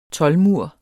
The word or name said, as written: toldmur